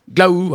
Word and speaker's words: glaou: Glau, oui, ça s'écrit G-L-A-O-U.